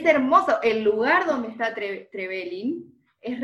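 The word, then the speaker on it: Trevelín: Estuve googleando bien, bien dónde está, ¿cómo se dice? ¿Trevelin o Trevelin? ¿Dónde va como el acento? – Trevelin. – Trevelin, ok. – Trevelin, okay. Sí.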